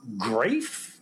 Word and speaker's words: Graeff: So anyway, the film is directed, written, produced, and edited by one Tom Graeff. Is that G-R-A-E-F-F, I guess? – Yep.